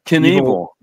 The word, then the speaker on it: Knievel: It's Knievel.